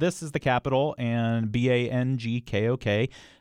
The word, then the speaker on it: Bangkok: It's kind of more like Bangkok, and it's a very short bang, if you will, and a longer gok. So it's like Bangkok.